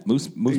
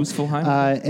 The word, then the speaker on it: Muspelheim: With the fire from Muspelheim. – Yeah, Muspelheim. – Yeah, there you go. – Muspelheim.